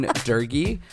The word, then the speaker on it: Durgy: Mine is Ryan Durgy, R-Y-A-N. – It's your name. – D-U-R-G-Y, because I am original and creative like that.